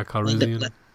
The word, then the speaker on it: Calrissian: Calrissian? – Yeah, Calrissian.